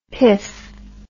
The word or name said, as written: piff